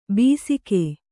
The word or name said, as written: ಬೀಸಿಕೆ